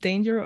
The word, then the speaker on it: Danger: Is it pronounced danger or danger? – Probably not. It's probably pronounced danger. – Danger. – Like that. Something really boring, you know.